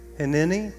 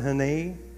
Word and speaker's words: Hineni: Hineni. Some pronounce it Hinei. Scholars pronounce it both ways, Hinei or Hineni.